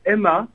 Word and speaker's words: M/A: Alors en fait, on prononce pas Ma, on prononce Emma. – Comme le prénom. – Oui, comme le prénom, Emma.